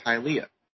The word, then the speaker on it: Hialeah: We were actually Googling on how to say it, and apparently it's Hialeah.